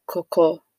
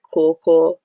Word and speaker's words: Korkor: but my real name is Coco Ashoganian. That's how you would say it, but in England I just tell everyone to pronounce it Coco. It's spelled Korkor, and it's a Dangme name.